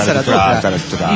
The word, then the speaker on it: Zaratustra: Es Zaratustra.